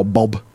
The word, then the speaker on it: B.o.B: What do you know about the hip-hop star Bob? – Bob. What I do know about Bob is that I think it should be pronounced B-O-B. – B-O-B.